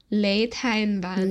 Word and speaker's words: Lê Thanh Vân: Le Thanh Van.